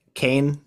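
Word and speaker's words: Caen: He's playing with, is it Kane? Is that how you would pronounce it in French? I don't know, guys. – That's how I'd say it.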